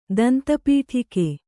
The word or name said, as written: ದಂತಪೀಠಿಕೆ